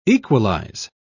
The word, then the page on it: equalise: British English